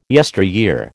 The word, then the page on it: yesteryear: American English